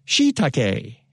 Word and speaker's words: shiitake: Or I looked up the pronunciation, and they had the pronunciation. The voice says it for it. You, it's shiitake.